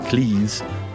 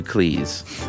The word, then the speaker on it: Cleese: He noted that his name is pronounced to rhyme with cheese rather than fleece. In fact, his surname was originally Cheese, but his father changed it to Cleese.